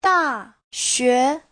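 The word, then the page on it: daxue: da4xue2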